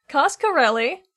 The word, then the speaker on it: Coscarelli: How do I say his name again? I wrote it down. Costco, the Don Cos Coscarelli.